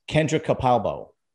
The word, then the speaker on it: Capalbo: I have today a special guest, Kendra Capalbo. Did I pronounce that right? – You did.